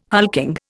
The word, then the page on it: hulking: Indian